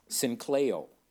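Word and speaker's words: synkleo (συγκλείω): Now, in the Greek, it's pronounced synkleo.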